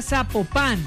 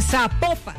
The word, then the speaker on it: Zapopan: Oiga, nos escriben, nos escriben los oyentes y que no se, no se pronuncia Zapopan, sino que se pronuncia Zapopan.